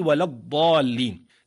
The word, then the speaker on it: ولا الضالين: إذن حينما سمعنا ولام ولض من قوله ولا الضالين لمجاورتها الضاد، لأنك في اللفظ تنطق الضاد بعد اللام مباشرة من قوله ولا الضالين، لئلا يسبق اللسان إلى تفخيم ما بعدها.